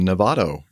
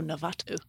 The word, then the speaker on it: Novato: Uh, they are having a meetup in Novato, uh, Marin County. – Novato. – Novato. – Nevada. Novato. Oh, Novato. – N O V A T O. I said it right.